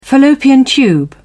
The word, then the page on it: fallopian tube: British English